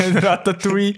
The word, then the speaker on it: Ratatouille: ja, also Rout Routatouille. Ratatouille ist und äh,